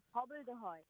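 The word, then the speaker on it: hobbledehoy: B-B-L-E-D-E-H-O-Y. Hobbled ahoy.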